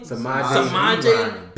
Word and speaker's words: Samaje: Samaje P-Rhyme.